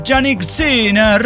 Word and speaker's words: Jannik Sinner: uno de los cuatro Grand Slams, y lo ganó un italiano, Jannik Sinner. – Jannik Sinner. – Sí, no es muy italiano, a lo mejor si le doy una pronunciación, Jannik Sinner.